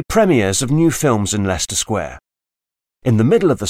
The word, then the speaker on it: Leicester Square: Leicester Square, pronounced Leicester Square, is in London's West End.